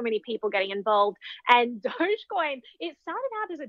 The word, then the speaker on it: Dogecoin: So most people will pronounce it Dogecoin. There is a contingent that pronounces it Doggycoin because it's originally based on the meme that was like a misspelling of the word dog.